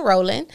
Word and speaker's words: Rollin: The G is silent. So it's no G on Rollin.